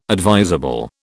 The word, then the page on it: advisable: British English